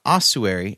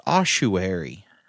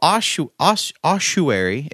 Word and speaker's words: ossuary: It's not ossuary. It's actually supposed to be pronounced ossuary. Ossuary. – You should spell it for somebody because nobody knows this word. – So ossuary is spelled O-S-S-U-A-R-Y.